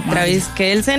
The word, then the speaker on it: Travis Kelce: Travis Kelce, ¿no? Así se pronuncia. – Sí, sí, Travis Kelce es un jugador de fútbol NBA, americano.